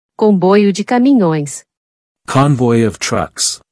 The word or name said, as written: Comboio de caminhoes